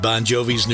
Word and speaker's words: Bongiovi: Won't you take me to Pronounced Bon Jovi, but it's spelled B-O-N-G-I-O-V-I. Phonetics in America, the Italian G-I is a J sound.